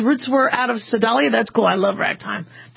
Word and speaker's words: Sedalia: Yes, yeah, Sedalia rhymes with Dr. Dahlia.